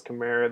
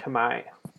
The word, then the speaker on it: Khmer: Did think it was Khmer. That is how English speakers pronounce it. But it is actually pronounced Khmer.